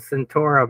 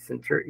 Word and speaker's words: Centauri: It's spelled Centaura, but it's Centauri.